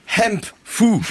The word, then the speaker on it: hemp fou: C'est le hemp fou. Est-ce que je prononce bien? – Oui, mal, avec le H aspiré, très bien.